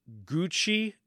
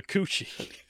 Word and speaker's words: Cu Chi: But the part that was funny to me was technically it is pronounced Gucci District, the Gucci District of Saigon, but it's okay. Not spelled that way. Okay. It's spelled C-U-C-H-I.